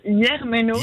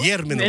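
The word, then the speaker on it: Germeno: Porto Germano. Ouais, alors à la base, on prononce Yermeno. – Yermeno, ah, pardon. – Voilà, mais je sais que personne ne le dira.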